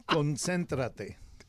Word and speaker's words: Concéntrate: seriously, how do you say focus in Spanish? – Concéntrate. – Concéntrate? – Concéntrate. – Concéntrate. – Concéntrate. No. Concéntrate. – Concéntrate. – Concéntrate. – No. – No? – I can't get it.